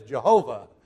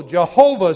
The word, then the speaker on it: Jehovah: And in Germany first, they began to say Yahweh, but since in German J is a Y, when we took it into English, we began to pronounce it as Jehovah.